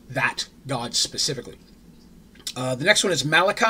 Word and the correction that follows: Malika: I'm oh, sorry, Malika. I said that wrong.